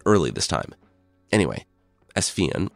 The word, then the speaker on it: Fionn: There's a lot of different spellings, and in some places he's called Finn McCool, but I've been assured Fionn is approximating the correct Irish pronunciation.